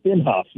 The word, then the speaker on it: Inhofe: Is it Inhofe? – Inhofe, yes.